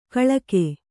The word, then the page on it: ಖಳಗ: khaḷaga